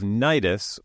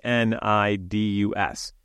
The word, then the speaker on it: Cnidus: Yeah, but before we get to that, uh, I'd like to note that we are talking about the Battle of Nidus, which is spelled C N I D U S. I expect throughout this episode, the silent C will be wreaking havoc with Victor's voicings. I think that it's pronounced Nidus, but I'm happy to be corrected if anyone out there actually knows how to say it.